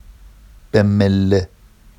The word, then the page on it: bemmelle: Vafsi